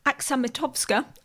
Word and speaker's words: Aksamitowska: And we've also got Karolina Aksamitowska, I hope that I'm saying that right, also in Swansea, where she's doing her PhD.